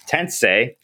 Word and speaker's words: Tensei: But I will be putting up on there this Shin Megami Tensei. There we go. Christian won't be mad at me. Not this rhymes with Sensei.